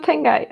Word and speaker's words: Tengai: Välkommen, uttalar jag det rätt, Tengai? – Tack så mycket. Men Tengai är det, precis.